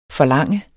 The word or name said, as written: forlangende